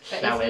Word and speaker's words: Llewyn: I've seen Inside Llewyn Davis, which always annoys me that it isn't pronounced Inside Llewyn Davis because it's the double L. It's a Welsh name. It should be pronounced Llewyn.